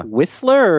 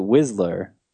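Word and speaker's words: Wisler: Is it Whistler or is it Whistler? Like, does it rhyme with Twizzler? – I thought it did.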